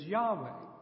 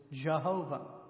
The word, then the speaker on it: Yahweh: And those words form together an anagram, and you, in the pronunciation of it, is Yahweh. Sometimes you'll hear me speak of Yahweh. You're probably more familiar with the term pronounced another way, and you do it another way, and then it is Jehovah.